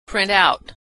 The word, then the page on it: print out: verb